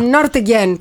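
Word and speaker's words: Nürtingen: Το είπα έτσι, ούτε νου ούτε νι. – Ναι, μπράβο, να είσαι μέσα. – Νούρτιγκεν, το είπα με προφορά.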